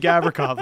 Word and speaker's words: Gavrikov: Whose Gavrikov. Last name rhymes with Mavrikov.